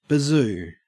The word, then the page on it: bazoo: Australian English